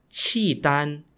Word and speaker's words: Khitan: Liao spelt L-I-A-O. The Liao dynasty was ruled by an ethnic group called the Khitan, spelt K-H-I-T-A-N, and is pronounced Qi Dan in Chinese.